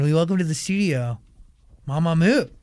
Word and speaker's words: Mamamoo: Just say comfortably, Mamamoo is fine. – Mamamoo.